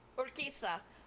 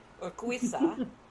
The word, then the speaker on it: Urquiza: And we've I have been distracting, running circles, talking in circles, with Kristen Urquiza. Say that right. Did I say that right? – Urquiza. – Urquiza. I'm sorry. I keep thinking this Latin thing with Q-U-I. Not Spanish. Urquiza.